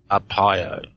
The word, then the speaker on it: Arpaio: Is that how you pronounce his name? – Arpaio. – Arpaio, okay.